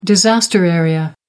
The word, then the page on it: disaster area: American English